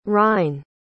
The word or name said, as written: rhine